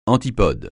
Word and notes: antipode: francais

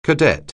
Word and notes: cadet: anglais

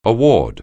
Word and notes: award: anglais

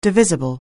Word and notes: divisible: anglais

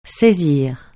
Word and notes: saisir: francais

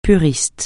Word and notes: puriste: francais

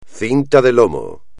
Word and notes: cinta de lomo: espagnol

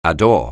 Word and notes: adore: anglais

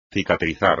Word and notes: cicatrizar: espagnol

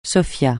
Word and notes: Sofia: francais

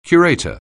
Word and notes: curator: anglais

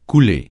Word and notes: couler: francais

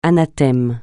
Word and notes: anathème: francais

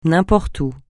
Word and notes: n'importe où: francais

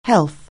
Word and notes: health: anglais

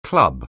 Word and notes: club: anglais

